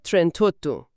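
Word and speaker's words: trentotto: یا مثلا ترنتا به اضافه اوتو میشه ترنتوتو. این یه جور زیبایی شناسی صوتی توی زبانه.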